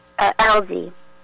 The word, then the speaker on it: Elsea: Alzi. – How you spell that? – E-L-S-E-A.